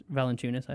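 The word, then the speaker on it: Valančiūnas: I'm sorry, Jonas Valanciunas. Valanciunas. I found out that's how it's pronounced.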